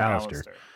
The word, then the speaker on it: Alastair: Yeah, it's spelled weird, but they pronounce it Alastair.